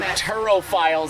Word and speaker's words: turophile: All so turophile is the official term for cheese connoisseur.